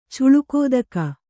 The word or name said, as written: ಚುಲುಕೋದಕ